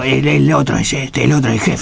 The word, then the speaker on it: Hetfield: Hetfield.